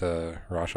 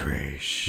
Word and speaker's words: Ra's: Well, you just have to get with Ra's al Ghul's daughter and then make your own. – You said his name wrong. It's Ra's.